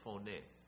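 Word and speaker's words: phoné (φωνή): And that's how you say it, phoné.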